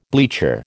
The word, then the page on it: bleacher: American English